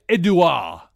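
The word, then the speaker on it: Édouard: So where I would say Eddie Mendy, you say Edouard Mendy. – I say Edouard Mendy, I say.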